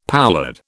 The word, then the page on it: poulard: British English